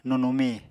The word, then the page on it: nunumi: Apatani